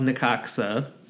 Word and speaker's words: Necaxa: Correct, and that's Club Necaxa. – Yeah. – Because I think that is how it's pronounced. – I think that's correct.